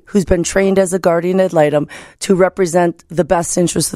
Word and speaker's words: guardian ad litem: But they wanted to know why guardian ad litem.